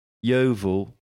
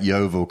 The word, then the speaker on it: Yeovil: Well, no, Dr. Seuss would never write Yeovil because it doesn't rhyme with anything. – Well, it's Whoville. – It rhymes with Whoville. It doesn't really rhyme with Whoville.